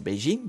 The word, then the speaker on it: Beijing: Enfin, c'est Beijing du coup, mais je sais, parce que c'est pas Pékin. On dit pas Pékin en chinois.